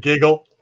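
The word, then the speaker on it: GIGL: She also has a band called Gig L, G-I-G-L, I think it's pronounced Giggle or Giggle. She'll correct. Giggle.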